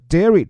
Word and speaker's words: dairy: 这个 Dairy 注意它的拼写啊，是 D A I R Y。我们说的那个日记叫做 D I A R Y，发音也不同。这里的乳制品、奶制品 Dairy，而这个日记叫 Diary。